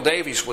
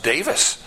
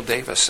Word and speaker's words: Davies: And that is, Samuel Davies was a Welsh. His parents were Welsh. And they came from Wales, moved to Pennsylvania. But in Wales, Davies is not pronounced Davies. It's pronounced Davis.